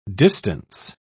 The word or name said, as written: distance